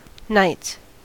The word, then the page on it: knights: American English